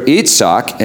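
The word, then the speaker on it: Yitzhak: Now, I neglected to mention to you before, Isaac, or Itzhak, and I think that's how it's pronounced in the Hebrew, it means he laughs, or one laughs.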